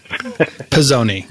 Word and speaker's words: Pisoni: I am very excited to be speaking with Adam Pisoni, who, I hope I said that right. I didn't even ask you. – Close enough. Pisoni. – Did I? Pisoni.